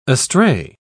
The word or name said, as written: astray